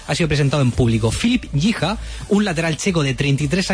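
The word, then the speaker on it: Filip Jícha: Así que vamos a escuchar cómo se pronuncia. – My name is Filip Gicha. – Gicha. – Filip Gicha. – Filip Gicha. – Gicha, muy bien.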